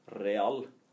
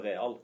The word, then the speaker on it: real: Altså, jeg forsøker å si real ja. istedenfor real. Ja. Altså minimal forskjell, og ingen bryr seg om en sier real.